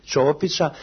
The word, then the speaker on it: Ćopića: Za mene neko, neke velike razlike između Ćopića, Ćopića, pravi akcent bi bio Ćopića, i Šekspira nema.